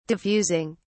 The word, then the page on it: diffusing: British English